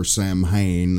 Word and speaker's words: Samhain: There's no old Cult of the Thorn, Samhain, or Samhain, as they pronounce it.